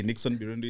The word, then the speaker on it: Birundu: I hope I've pronounced that name vizuri. – That's how it's supposed to be. Nixon Birundu is my name.